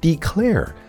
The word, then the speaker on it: declare: 那我们继续来看这个单词 declare，前缀 de 加强语气，中间的词根 CRAL 表示呼喊、大叫，对吧？加上一个不发音的字母 E 结尾，就是动词宣布、声明。